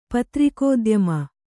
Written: ಪತ್ರಿಕೋದ್ಯಮ

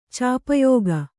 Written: ಚಾಪಯೋಗ

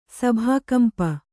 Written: ಸಭಾಕಂಪ

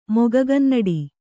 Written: ಮೊಗಗನ್ನಡಿ

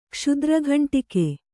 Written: ಕಸ್ತೂರಿಕಾಂಡಕ

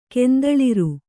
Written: ಕೆಂದಳಿರ್

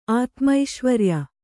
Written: ಆತ್ಮೈಶ್ವರ್ಯ

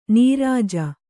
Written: ನಿರುಜ

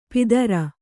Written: ಪಿತೃ

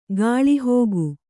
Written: ಗಾಳಿಹಾಕು

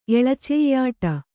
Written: ಎಳಚೆಯ್ಯಾಟ

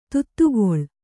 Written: ಧಾತುಗೊಳ್

